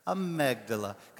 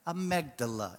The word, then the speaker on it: amygdala: Amygdala. Because it took me all week to learn how to say it properly, okay? Amygdala.